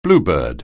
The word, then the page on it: bluebird: anglais